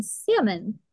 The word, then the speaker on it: salmon: You do not pronounce the L in salmon.